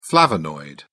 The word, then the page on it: flavonoid: British English